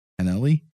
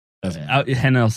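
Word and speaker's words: Hanelle: Hanelli? Whatever. – Hanel sounds like, I mean, it's spelled like Janelle with an H.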